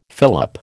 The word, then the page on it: fillip: American English